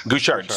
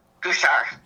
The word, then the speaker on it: Gouchard: Gouchard. – So James Lewis Gouchard? – Gouchard. – Yeah, Gouchard. Okay, silent D.